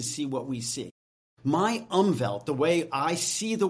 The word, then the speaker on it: Umwelt: There's a word I learned when I was researching this, and the word is spelled UMWELT. It's pronounced Umwelt.